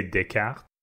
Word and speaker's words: Descartes: If it's French, I would say Descartes that sounds way more right.